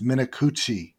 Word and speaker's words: Minicucci: He writes, "Guys, his name is Ben Minicucci. Pronounced Minicucci.